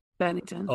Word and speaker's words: Bennington: Bennington. – Oh, from Lincoln Park? – Bennington, from Lincoln Park.